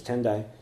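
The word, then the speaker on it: Tendai (天台): And the sect is called Tendai, which comes from a mountain in China called Tiantai. And so the Japanese pronunciation is Tendai, centered in Mount Hiei.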